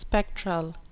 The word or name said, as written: spectral